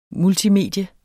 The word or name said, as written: multimedie